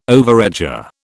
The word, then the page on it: overeager: British English